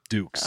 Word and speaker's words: Dux: D-U-X, who is Jean-Claude Van Damme from Bloodsport. – Frank Dukes? – Dukes. – Oh, yeah. Jean-Claude Van Damme, easily. – Yeah, I'll go with Frank Dukes.